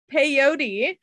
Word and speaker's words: peyote: I've never heard that term rhyme with coyote. – That's what I was saying. – Coyote. – I said it like coyote. Well, that's embarrassing. So they took peyote.